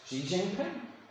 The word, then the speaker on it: Xi Jinping: We have, for instance, Xi Jinping. That is the right pronunciation of the Chinese premier, prime minister, whatever you call him.